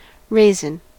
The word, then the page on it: raisin: American English